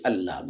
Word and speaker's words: Allah: So you'll have you'll say al illa. You wouldn't say Allah. The fact that you say Allah means that it's not a combination of al and illa.